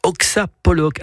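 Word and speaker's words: Oksa Pollock: Oxapolloc, alors soyez pas surpris, hein, ça s'écrit O-K-S-A, entre guillemets plus loin, Pollock, P-O-2-L-O-C-K.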